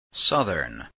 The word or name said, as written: southern